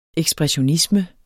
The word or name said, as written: EKSPRESSIONISME